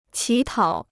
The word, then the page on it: 乞讨: qǐ tǎo